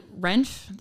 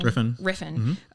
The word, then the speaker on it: Refn: Well, when like Renf, is that how you say his name? – Refn.